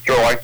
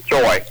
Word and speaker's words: Troy: Troy. – Troy? – Troy. – What's that? – Troy. – Spell that for me. – T-R-O-Y.